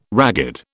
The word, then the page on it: ragged: British English